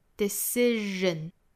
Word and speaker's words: decision: 后面这个 decision, 中间的这个 s 应该是读一个日的发音 ,decision, 人 ,decision。